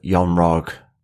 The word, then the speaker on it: Yon Rogg: In the festive spirit that we're still in, I do feel that Yon Rogg... Yeah, well, it does. It rhymes with eggnog. – Jan Rog, yes, Jude Law returning from from his time in Captain Marvel.